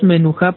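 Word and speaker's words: menujá (מנוחה): Nosotros pronunciamos menujá porque así está escrito, así está mencionado.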